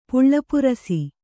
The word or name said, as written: ಪುಳ್ಳಪುರಸಿ